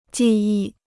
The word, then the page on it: 技艺: jì yì